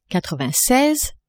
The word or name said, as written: quatre-vingt-seize